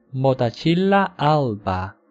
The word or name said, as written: Motacilla alba